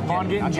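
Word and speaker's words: Vaughn Gittin: But so just in case people didn't get it, Von Gittin. Did I say that right? – Von Gittin Jr. Okay, yes.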